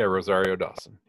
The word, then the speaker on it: Rosario Dawson: Is it Rosario Dawson? – Yeah. Rosario Dawson.